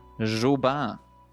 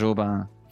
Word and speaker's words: Joubain: Jobin. – Nei. Jo... Hvordan Sånn at det skrives J-O-U-B-A-I-N. Du kan skrive J-O-B-æ som uttale om du ønsker. – Parentes Jobin. – Jobin.